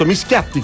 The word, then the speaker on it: Mischiatti: Ad esempio abbiamo anche, per esempio, Ugo Mischiati, ho detto giusto, Mischiatti con due T.